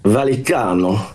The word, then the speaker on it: Vallecano: Listen, have you got the pronunciation right? Because I'm struggling, I've got to tell you. – Vallecano. – There you go. There you go. You've got it.